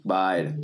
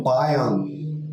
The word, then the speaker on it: Bayern: Bayern, tamo junto. Gostou da pronúncia? – De novo? Bayern.